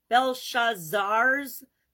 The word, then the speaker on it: Belshazzar: So this one comes from King Belshazzar's, however you pronounce it, Belshazzar, apostrophe S, Belshazzar's, yeah, Belshazzar's feast.